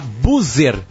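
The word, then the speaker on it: Buser: A Buzzer, se escreve com S, B-U-S-E-R.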